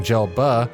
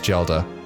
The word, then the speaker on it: Gelda: His wife, Gelda, who we also started calling Gelba, was... Well, it is Gelda, okay? It's like Zelda with a J.